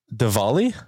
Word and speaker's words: Diwali: Diwali. And I was, yeah, that I'm saying that right. – Pronounced the W's a V. – Diwali? – Yes.